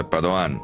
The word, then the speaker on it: Padoan: Devo dire che sono d'accordo con Stella, è veneto e si pronuncia Padoan, così come Lorenzin, la pronunciamo Lorenzin, anche se non è cresciuta in Veneto, se no dovremmo dire Lorenzin e Padoan, Padoan e Lorenzin, fine.